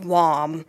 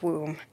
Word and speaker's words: womb: although I insisted at the time that it was pronounced WOM and was arguing with my mother, who said, no, dear, it's pronounced womb.